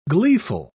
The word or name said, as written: gleeful